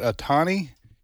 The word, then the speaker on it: Otani: But that Otani, he hit two home runs last night. The Japanese fella. – Yeah. – Did I pronounce his name right? – Okay. You did. – Otani, and then he also got on base.